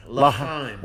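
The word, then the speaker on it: L'chaim: Lachaim. – Lachaim.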